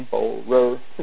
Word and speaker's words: Spohrer: And also Google is a good start right now, that if you Google Jim Sporer, and his name is spelled S-P-O-H-R-E-R, Sporer,